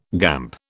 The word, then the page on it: gamp: British English